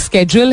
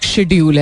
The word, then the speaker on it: schedule: because uh, अंग्रेजी में दो प्रोनाउंसिएशन इसकी एक स्केड्यूल है और एक शेड्यूल है बट द वर्ड द बेहतर प्रोनाउंसिएशन इज स्केड्यूल